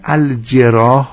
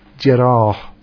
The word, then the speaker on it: الجراح: این کلمه الجراح رو با همین تلفظی که دارم عرض میکنم شما هم تلفظ بفرمایید. الجراح میدونید جمع جراحت است، وزن فعال یکی از کارهایی که انجام میده برای جمع است، جراح. خب این به اصطلاح به کسره جیم بخوانیم میشود جراح. بعد فرمودن مالم یبلغ نفس، در شهادت بر جراحت دو تا قول مطرحه.